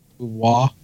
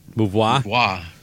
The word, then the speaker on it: Beauvoir: Is it Beauvoir? – Beauvoir. – Beauvoir.